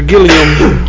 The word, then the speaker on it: Gilliam: I don't know who Wade Gilligan is or Gilliam. – Gilliam.